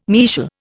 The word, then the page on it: mesial: Indian